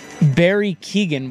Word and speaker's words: Barry Keoghan: And then Barry Keoghan will play Ringo Starr.